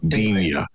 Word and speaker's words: Digne: You've changed the way you pronounce his name to Digne now. Bloody hell! It only took you about 5 years. Finally, you're going with the everyone's agreed pronunciation of his name, Digne.